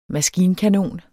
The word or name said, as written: maskinkanon